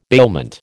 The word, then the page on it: bailment: American English